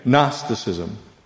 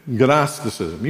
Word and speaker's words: Gnosticism: Can you say that? It's GN, Gnosticism. It's like GNU, Gnosticism. You can pronounce it like that if you want.